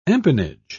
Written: empennage

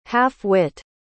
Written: half-wit

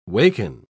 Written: waken

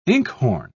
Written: inkhorn

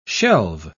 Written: shelve

